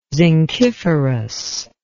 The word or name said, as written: zinciferous